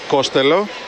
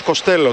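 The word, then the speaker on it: Costello: Πώς προφέρει το όνομά σου, λέει Ματ Κοστέλο. Τότε λέει γιατί σε βονάζουμε έτσι, γιατί λέει κάποτε σε έναν δημοσιογράφο είχα πει Κόστελο γιατί του φάνηκε πιο εύκολο. Δηλαδή ο ίδιος ο παίκτης μας μπέρδεψε.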